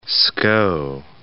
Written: Skou

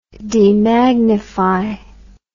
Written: demagnify

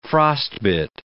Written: frostbit

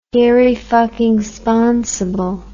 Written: irrefuckingsponsible

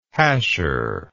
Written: hasher

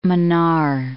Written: Mannar, Gulf of

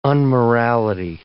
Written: unmorality

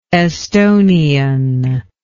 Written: esthonian

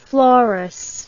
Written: florous

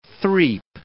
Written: threap

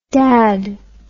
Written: dadd

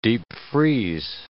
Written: deep-freeze

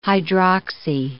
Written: hydroxy

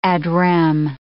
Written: ad rem

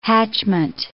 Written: hatchment

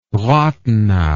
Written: rathenau

